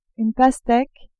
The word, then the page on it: pasteque: French